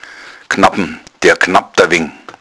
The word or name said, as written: gnabbn